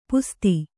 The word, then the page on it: ಪಸದ: pasada